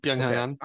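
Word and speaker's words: Byeong-hun An: I cannot pronounce his name well, but it's like Byung Hyun An. – Yeah. Mm-hmm. Byung Hyun An. – All right, cool. So I got it right.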